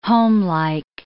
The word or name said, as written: homelike